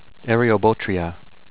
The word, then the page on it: Eriobotrya: err-ee-oh-bow-tree-uh